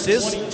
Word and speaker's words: Cisse: It's A-B-O-U-B-A-C-A-R, pronounced Abukar, and Sis is C-I-S-S-E, pronounced Sis.